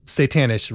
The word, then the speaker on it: Satanish: And he makes all of the, Satanish rhymes with vanish, like make your weapons vanish.